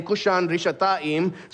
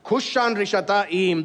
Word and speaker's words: Cushan-Rishathaim: In Hebrew, Mesopotamia there is the longer name is given, which is Aram Naharaim, which rhymes with his name, Cushan-Rishathaim. So even as you hear his name being proclaimed, you should feel the sense of, this is ominous. Cushan-Rishathaim, the man from Aram Naharaim.